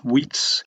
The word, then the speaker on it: Wietse: Wheats is spelled W-I-E-T-S-E.